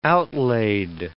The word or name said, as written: outlaid